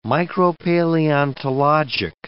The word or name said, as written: micropaleontologic